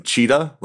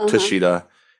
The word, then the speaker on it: Cheetah: My name is Jason Cheetah, like the animal, not Tashita.